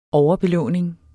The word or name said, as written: overadministration